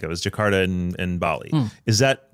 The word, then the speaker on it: Jakarta: Jakarta. – Is that right? Yep. Yeah, Jakarta.